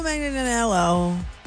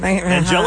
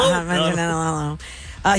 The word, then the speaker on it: Manganiello: All right, so Joe Manganiello. That was how you say his name. – Angelo? – Manganiello.